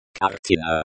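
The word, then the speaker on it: kartina (картина): The Russian word for picture is kartina.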